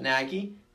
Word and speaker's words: Nagy: should their head coach Matt Nagy, is that how you pronounce yep. it? Nagy